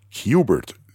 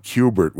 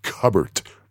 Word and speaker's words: Qbert: or else someone's going to think it's pronounced Cubbert. So what he did was he suggested changing the spelling to the letter Q and then Bert to make it a little bit more obvious as to how you're supposed to pronounce it.